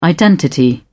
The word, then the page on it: identity: British English